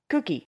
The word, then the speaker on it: koekje: The word cookie comes from the Dutch word cookie, which, while pronounced like cookie, is spelled K-O-E-K-J-E, and it meant small cake.